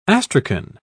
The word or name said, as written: astrakhan